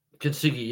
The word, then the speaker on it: Kintsugi: Kintsugi, yeah.